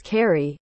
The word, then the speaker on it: Ceri: His middle name is Ceri, pronounced with a K, to distinguish him from other Welsh authors with the same name alive or deceased.